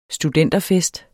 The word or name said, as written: studenterfest